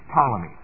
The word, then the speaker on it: Ptolemy: Now here's the name you need to remember. Sounds like Ptolemy, or it's spelled like Ptolemy, but it's Ptolemy. P-T-O-L-E-M-Y.